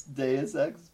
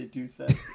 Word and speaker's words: Deus Ex: How do you say it? – It's Deus Ex, but I like when you say Deus Ex. – I've never once heard anybody pronounce it Deus Ex.